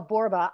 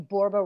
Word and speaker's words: Borba: Well, my name's Michelle Borba. I'm a 1L Michelle, and my last name, Borba, rhymes with Zorba.